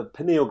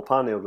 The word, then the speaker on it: pineal: It's also believed that the pineal gland or pineal gland, depending on how you want to pronounce it, is actually your subconscious.